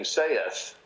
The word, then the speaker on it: saith: And then I show them the pronouncing dictionary of American English, in which S-A-I-T-H is given only one pronunciation, which is with the epsilon in it. – Exactly. – No diphthong.